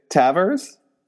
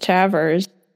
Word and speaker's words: Tavares: How's it spelled? – T-A-V-A-R-E-S. – I think it's just Tavers.